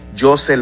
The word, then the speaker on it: Jhosse: José Lora Jr. Se escribe J-H-O-S-S-E Lora, J-R, y así voy a aparecer en Facebook, en TikTok, en Instagram, en también el canal de YouTube.